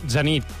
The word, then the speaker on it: Zenit: És a dir, mira, ho hem consultat, el servei lingüístic d'aquesta casa, i diuen que admeten la pronúncia plana, que seria Zenit.